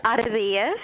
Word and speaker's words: Arradillas: It's Arradillas.